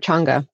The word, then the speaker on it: Changa: My personal experience was with, if I remember right, it was a Chaga experience I had, or Changa, pardon me, saying that wrong.